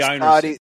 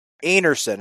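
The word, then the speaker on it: Einarson: Carrie Einerson. Are you surprised I know that name? – Einerson, it's pronounced, not Einerson.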